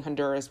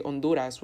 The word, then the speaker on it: Honduras: I hate saying Honduras by pronouncing the H. I don't know, my brain. I remember once my teacher said, if you're ever asked, like, where are you from, you got to say Honduras without pronouncing it like an American.